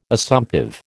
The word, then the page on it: Assumptive: American English